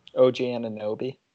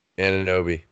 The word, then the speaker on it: Anunoby: OG Anunoby. – Anunoby.